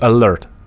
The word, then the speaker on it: Алерт: Мне, кстати, сегодня сказали, что правильно говорить «Алерт».